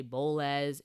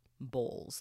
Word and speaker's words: Bowles: So my name is Ashley Antonia Bowles, formerly Ashley Antonia Williams, but I got married and now my last name is Bowles. It's spelled B-O-W-L-E-S. Some people say Bowles. Some people say Bowles. It's literally pronounced like Bowles, like the dish bowl, but with an S, Bowles.